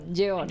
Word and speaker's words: Jeon: Nah, Jeon apa John? Jeon. – Jeon ya bacanya ya.